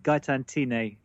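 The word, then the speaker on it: Gaëtane Thiney: Gaëtan Tinny is the correct pronunciation, but you got the surname right.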